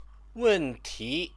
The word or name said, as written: wenti